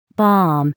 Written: bomb

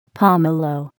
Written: pomelo

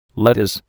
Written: lettuce

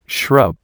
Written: shrub

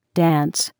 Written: dance

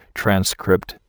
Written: transcript

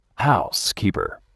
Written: housekeeper

